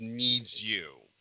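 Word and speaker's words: NiziU: How do you, is it, okay, it's supposed to be pronounced Needs You.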